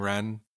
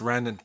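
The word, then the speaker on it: Refn: And Nicholas, I always forget, is it Nicholas Winding Wren? – Yeah, I think it's like a silent F in his name somewhere. – Well, Cliff Martinez, I believe, is the composer, but Nicholas Nicholas Wren ends up being the director of all those films.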